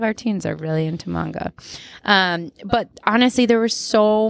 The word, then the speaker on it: manga: Am I saying that right? Manga or manga? Manga. – I think so, yeah.